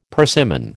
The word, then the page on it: persimmon: American English